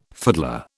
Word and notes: fiddler: British English

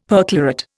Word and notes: perchlorate: Indian

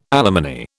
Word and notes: alimony: British English

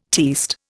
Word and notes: tierced: Indian

